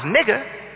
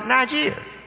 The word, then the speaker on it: Niger: Call N-I-G-E-R. But it's not pronounced nigger. It's pronounced Niger. Niger. Not nigger.